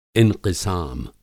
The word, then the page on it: इंक़िसाम: Hindi